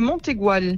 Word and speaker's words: Mont Aigoual: Est-ce que j'ai bien prononcé? C'est bien Le Mont Aigoual? – On fait la liaison, on dit le Mont Aigoual.